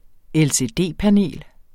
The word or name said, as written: LCD-panel